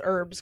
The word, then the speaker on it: herb: Like that's it's kind of like how they can't pronounce herbs correctly. How do they say herbs? Herb you don't say the H.